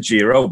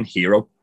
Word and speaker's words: Jiro: So, depending on which commentator, like he pronounced it Jiro, but then Vic kept saying Hero. – Well, Vic's wrong, because he's not pronouncing his own name wrong.